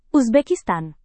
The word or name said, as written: uzbekistan